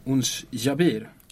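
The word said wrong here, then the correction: Ons Jabeur: Ons Jabör, ja. Jabör.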